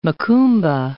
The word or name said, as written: macumba